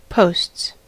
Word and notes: posts: American English

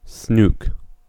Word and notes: snook: /snuːk/

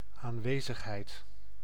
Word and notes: presence: American English; [ˈpɹɛz.əns]

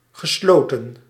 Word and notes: privé: [pʁi.ve]; France

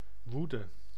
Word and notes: fureur: France; /fy.ʁœʁ/

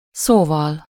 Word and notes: well: /ˈwɛl/; [wɛl]

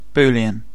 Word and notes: Boolean: [ˈbu.li.ən]

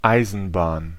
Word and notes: railroad: American English; [ˈɹeɪl.ˌɹoʊd]

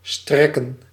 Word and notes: spread: /spɹɛd/

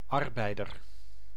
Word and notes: arbeider: [aɾ.bɛj.dœɾ]; Dutch

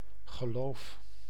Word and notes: geloof: [ɣə'lof]

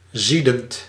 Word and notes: furieux: France; [fy.ʁjø]

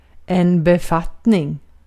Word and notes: position: /pəˈzɪʃən/

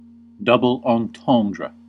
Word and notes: double entendre: /dubl ɑ̃tɑ̃ːdɹ/; /dʌb.əl ɒnˈtɒndɹə/

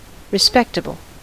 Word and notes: respectable: [ɹi.ˈspɛk.tə.bl̩]; American English

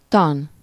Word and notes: theory: /ˈθɪə.ɹi/

